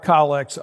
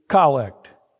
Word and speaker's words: collect: So over a, a year, there are approximately 65 collects uttered in an Anglican church. The proper pronunciation is collect. So if someone says collect, give them a Mona Lisa smile.